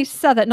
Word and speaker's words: Southwark: And she went on to tell us, "I just listened to the podcast and I used to work near Southwark." Because we say Southwark, not Southwark as I did. So you don't really pronounce the O or the W.